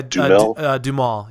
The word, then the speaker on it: Duhamel: I was super excited to see, I can never pronounce his last name correctly, but I think it's Josh Duhamel. – Oh, yeah, Duhamel.